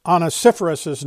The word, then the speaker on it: Onesiphorus's: And so this week I found out that I'd been mispronouncing Onesiphorus's name. That's how you pronounce his name.